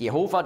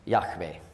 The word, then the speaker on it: Yahweh: Das bestätigt, dass nicht Jehova die richtige Aussprache ist, sondern Yahweh.